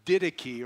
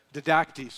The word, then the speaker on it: Didache: It was called the Didache, or some pronounce it the Didache.